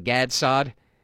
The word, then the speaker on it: Saad: I know his last name is pronounced Saad. Gad Saad.